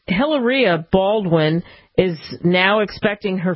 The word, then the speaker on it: Hilaria: Yes, and I do believe his wife's name is pronounced Hilaria.